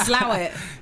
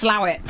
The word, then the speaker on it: Slaithwaite: You see how I had the proper pronunciation there? Slawit. – Yeah, it's spelled Slaithwaite. But it's pronounced Slawit.